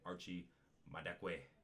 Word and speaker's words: Madekwe: It stars Barry Keoghan, Rosamund Pike, Archie that's an African name. I gotta pronounce that right. Archie Madekwe. Yeah, there you go.